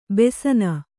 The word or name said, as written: ಬಸನಿ